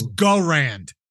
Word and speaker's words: Garand: I'm choosing the M1 Garand. Most popular. – First off, it's Garand. – Go Rand. – Jesus Christ. – Go. – You lose points for mispronunciation.